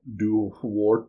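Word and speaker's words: Dobharchú: And I guess the pronunciation, it's spelled Dobarchu, but the pronunciation would be Duwarchu, because Irish is...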